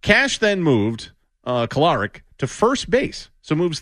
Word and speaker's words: Kolarik: Is that how you say it? Kolarik. – Kolarik. Adam Kolarik, a lefty.